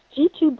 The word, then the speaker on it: Jitu: It's G2 Brown. – D2? – J-I-T-U. And it sounds like the letter G and the number 2, but it's spelled J-I-T-U. – J-I-T-U Brown.